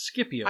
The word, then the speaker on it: Scipio: Because, for example, there is a family of generals from Rome that are often pronounced as the Scipio. – Scipio. – Right, and I always pronounce it as Scipio.